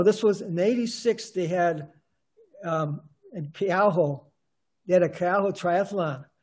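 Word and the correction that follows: Keauhou: Keaho. I pronounced that wrong. Keaho, yeah, and that was...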